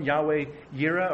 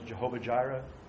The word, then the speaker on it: Yahweh Yireh: Yahweh Yireh, or sometimes you know as Jehovah Jireh, depending on the pronunciation.